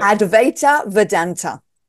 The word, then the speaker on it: Advaita Vedanta: Advaita Vedanta is.